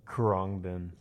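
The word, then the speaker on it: Khruangbin: And right before that, to start off the show, was Krugabin. How do you say that? – Krongbin. – Kromden? – Krong. – Krom. – Bin. – Bin. – K-H-U... – No, K-H-R... U-N-G-B-I-N. Krongbin.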